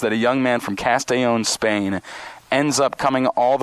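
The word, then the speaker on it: Castellón: Is it Castellon, Pablo? Is that how you pronounce it? – I'm from Spain. I'm from Castellon.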